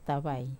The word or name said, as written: tavai